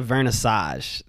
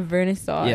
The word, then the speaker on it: vernissage: So they call it a vernissage. That's how it's said in Deutsch. – A vernissage.